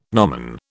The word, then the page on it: gnomon: British English